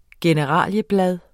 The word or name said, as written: generalieblad